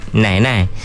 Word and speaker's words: Nanai: Dia sebut nai-nai. – Nai-nai. – Hak ni memang ejaan dia N-A-N-A-I. – Nanai. Macam nanas tu.